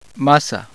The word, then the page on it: a: Spanish